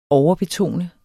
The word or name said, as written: overbetone